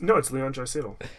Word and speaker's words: Leon Draisaitl: No, it's Leon Draisaitl.